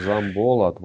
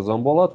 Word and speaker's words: Замболат: Смотрите, э, угадайте, где ударение. Замболат. – Замболат. – Да, Замболат, вот на О небольшое ударение.